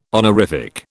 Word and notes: honorific: British English

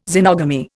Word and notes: xenogamy: Indian

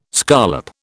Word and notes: scollop: British English

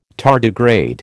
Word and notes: tardigrade: American English

